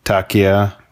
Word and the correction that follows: Taika Waititi: It's like Taika Waititi or something. I don't know. – Taika Waititi. – It's a Maori name.